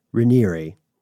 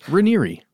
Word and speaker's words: Raniere: It is the brainchild of founder Keith Raniere, which is how we found it pronounced in multiple media sources. – Raniere. – Raniere.